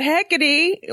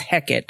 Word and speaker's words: Hecate: Hecate well, okay, so like for Shakespeare, it would just be pronounced Hecate, but you know, it's actually Hecate.